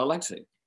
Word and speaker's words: Alexei: So I'm a little bit confused, but I am pronouncing it correctly. Alexei, correct? – Yeah, Alexei.